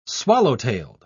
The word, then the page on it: swallow-tailed: British English